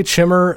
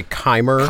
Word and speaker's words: Chimer: But all you need to know is that the Chimmer is how I'm going to pronounce it.